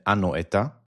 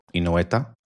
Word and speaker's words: אנואטה: הנה אחרונה, אתה פשוט butchering the name, זה אנואטה, לא אינואטה.